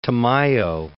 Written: Tamayo

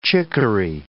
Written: chickaree

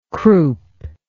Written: croupous

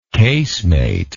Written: casemated

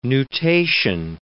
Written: nutation

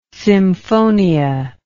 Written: symphonia

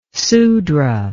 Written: sudra

